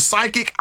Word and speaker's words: psychic: So you're right, because when we see psychic, it's spelled P-S-Y, right? – I couldn't tell you. – Yes, yeah. Yep. – So the P is silent.